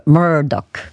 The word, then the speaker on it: Murdoch: Comunque Murdoch, così si pronuncia, e quindi spero di ricordarmelo per le prossime volte, perciò grazie a chi me l'ha segnalato.